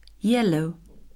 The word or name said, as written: yellow